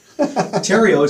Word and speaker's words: Terrio: Terrio, as you say, stereo, but leave off the S.